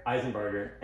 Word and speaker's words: Isenbarger: Isenbarger.